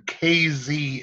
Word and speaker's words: Casey: Anyway, one of the biggie events that's coming around, I believe it's next year, is in KZ, Illinois. – Yes, and it's spelled C-A-S-E-Y.